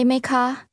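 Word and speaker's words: ไหม คะ: คุณเข้าใจภาษาไทยไหมคะคะไหมคะ is pronounced with two high-level tones.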